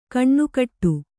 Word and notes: ಕಣ್ಣುಕಟ್ಟು: kaṇṇukaṭṭu

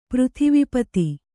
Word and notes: ಪೃಥಿವೀಪತಿ: kannada

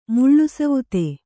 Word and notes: ಮುಳ್ಳುಸವುತೆ: kannada

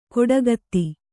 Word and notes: ಕೊಡಗತ್ತಿ: koḍagatti

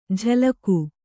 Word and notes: ಜಲಗು: Kannada

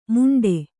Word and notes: ಮುಂಡೆ: muṇḍe

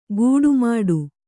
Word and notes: ಗೂಡುಮಾಡು: gūḍu māḍu

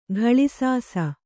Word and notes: ಕಳಾಸಿಸು: kaḷāsisu

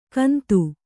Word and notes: ಕೌಂದ: Kannada

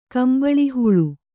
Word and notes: ಕಂಬಳಿಹುಳು: Kannada

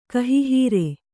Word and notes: ಕಹಿಹೀರೆ: Kannada

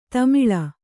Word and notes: ತೆಮಳ್: Kannada

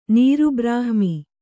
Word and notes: ನೀರುಬ್ರಹ್ಮಿ: nīru brahmi; kannada

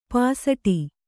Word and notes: ಪೋಸ್ಟು: Kannada

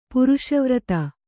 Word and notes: ಪುರುಷವ್ರತಿ: puruṣa vrati; noun

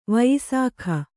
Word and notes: ವಯಸ್ಕ: vayaska

